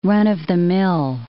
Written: run-of-the-mill

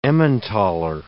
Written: Emmentaler

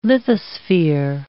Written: lithosphere

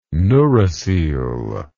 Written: neurocoele

